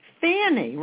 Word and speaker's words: Fannie: Fanny with an F like Frank. – Can you spell it? – F-A-N-N-I-E. – Fanny, right? – Yeah, you know, like you sit on your fanny.